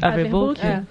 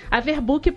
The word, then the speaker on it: Averbuck: Averbuck. Averbook para cariocas, né? Averbook para paulistas.